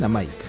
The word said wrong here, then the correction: Amaike: Amaike. – Amaike, perdón, no Amaika.